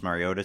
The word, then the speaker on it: Mariota: By the way, I've been taking some crap from some Oregon fans that I keep mispronouncing his last name. I've been mispronouncing his last name for 4 years. Mariota, not Mariota, Mariota.